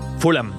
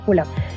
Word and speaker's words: Fulham: Fulham. – Ah, Fulham.